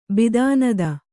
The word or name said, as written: ಬಿದಾನದ